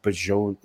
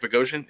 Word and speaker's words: Bogosian: And I actually said his name wrong. Yeah. I was like Bajon, like whatever, because it was a weird spelling, you know. – Eric Bogosian. Yeah.